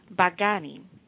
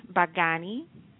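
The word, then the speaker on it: bagani (बैंगनी): The third color is going to be violet, and violet is pronounced bagani. Say it again, bagani, and that is spelled B as in boy, A-I-N as in Nathan, G as in ground, A-N-I. So B-A-I-N-G-A-N-I, and it's bagani, and that's violet.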